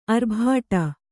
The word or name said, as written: ಆರ್ಭಟ